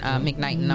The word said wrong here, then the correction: Knighton: I'm sorry. Knighton. Yeah, I said it wrong the first time, yeah.